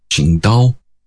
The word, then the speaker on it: Qingdao: it was written as T-S-I-N-G-T-A-O, their beer, Tsingtao. But the Chinese actually pronounce it as Qingdao. A slight difference. In modern pinyin, it would be written as Q-I-N-G-D-A-O. It's the name of a place, a city.